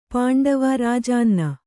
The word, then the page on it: ರಾಜಾನ್ನ: kannada